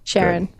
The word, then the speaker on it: Sharon: Kelsey, and how do I say your last name? Is it just like Sharon, like the first name Sharon, or is that it? – Sharon. – Good.